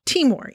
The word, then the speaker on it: Timor: Timor.